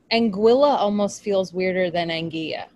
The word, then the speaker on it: Anguilla: Someone told us that Anguilla is pronounced Anguilla.